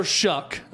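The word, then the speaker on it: Shough: Tyler Shuck, it's a weird pronunciation for how the name is spelled, but Tyler Shuck at quarterback, the Oregon transfer, I believe is going to get it done for Joey McGuire and that offense.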